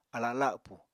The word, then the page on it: ala lapù: apatani